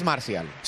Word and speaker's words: Martial: Es que se escribe Martial, Marcial. – Gracias, el francés. – Marcial. – Marcial. Marcial. – La castellanización es Marcial. – Sí. – Marcial. Vale. – La castellanización sería Marcial.